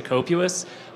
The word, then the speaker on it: COPUS: And if I'm introducing your office to your audience to COPUS, I'm going to try to cement that pronunciation because there's others out there and I don't want anybody else to use them.